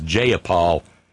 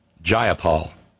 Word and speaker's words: Jayapal: Darlene in Connecticut says, I love how Pramila reamed out that horrid pig Debbie Lasko about her disrespectful pronunciation of her name, referring to as Jayapal with a long A multiple times. Pramila called her out, and I paraphrase, if you're going to quote me, pronounce my name properly, it's Jayapal with a long I.